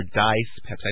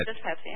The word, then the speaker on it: dyspepsia: the negative land dyspepsia or dice pepsi, I never know how to pronounce it, but dyspepsia, yeah.